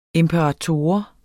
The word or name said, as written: imperator